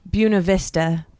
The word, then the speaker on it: Buena Vista: in Buena Vista, is how the locals say it.